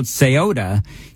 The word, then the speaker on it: Ceuta: It's called Ceuta, C-E-U-T-A, and I'm not sure if I'm saying it correctly. It's spelled C-E-U-T-A, and I did a pronunciation. It says Ceuta, but anyway, it is crazy.